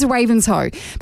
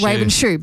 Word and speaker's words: Ravenshoe: I mean, there's Ravenshoe, but so many people call it Ravenshoe because it looks like it's Ravenshoe.